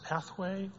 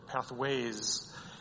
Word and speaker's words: Pathway: And the final question is it pronounced Pathway or Pathways? – So I don't correct people on this because I would say 95% of people say pathways, but there is no S. And truthfully, it is BYU pathway.